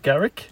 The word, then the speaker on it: Garrick: Uh, Reuben, oh god, how do I pronounce this? G- Garrick. – Yep, that's it.